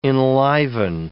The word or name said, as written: enliven